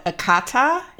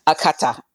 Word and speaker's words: Akata: called Akata. Did I say it correctly? – You want to put a little twang in it like you did before. Akata. She's Akata.